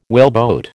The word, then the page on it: whaleboat: American English